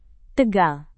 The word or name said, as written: tegal